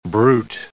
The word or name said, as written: brut